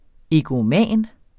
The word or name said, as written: egoman